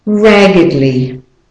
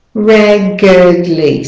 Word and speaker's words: raggedly: And the last one, raggedly. Raggedly. So the E is the schwa vowel, the weak syllable, and the A and the Y are the stressed syllables.